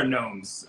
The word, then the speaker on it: gnomes: I think it's the underwear gnomes who take them. So that plays in really well because gnomes does start with a G and you don't pronounce it.